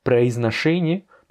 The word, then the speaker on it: произношение: Произношение, bah, tu vois qu'il y a deux O, de proizno, eh bien ces deux O-là, n'étant pas accentués, se prononcent comme des A. Произношение.